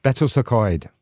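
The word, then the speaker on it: Betws-y-Coed: I can't really pronounce this, but I think it's called Betysycoed. – Betysycoed. – You did pretty good. – Oh, hey. – Say it again, in Welsh. – Betysycoed.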